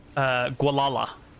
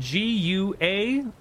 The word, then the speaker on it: Gualala: Gualala. – Stolen ring of Gualala. – Yeah, it's spelled Gualala. – Oh, Gua-la-la. I had that, actually.